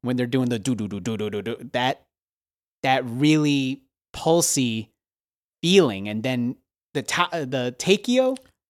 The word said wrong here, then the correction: taiko: Oh, yes, the taiko drums. – The taiko. Taiko.